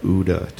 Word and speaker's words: OODA: Can you talk to me about OODA? Did I say that correctly? – Yes.